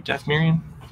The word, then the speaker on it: Dathomirian: Dathomirian? Am I pronouncing that right? – Yeah.